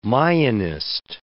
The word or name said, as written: Mayanist